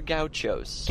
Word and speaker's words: Gaucho: Peanut Butter Gauchos. – Gauchos? – I don't know if I'm pronouncing that correctly. – Yeah, you are. Gaucho's correct.